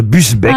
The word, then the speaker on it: Busbecq: Ogier Gilin de Busbeck, pardon, j'ai écorché son nom. – Il me semblait bien aussi. – Ouais. – Est-ce que vous pouvez appeler Busbeck? – B-U-S-B-E-C-Q. Olivier, pardon, Ogier Gislin de Busbeck.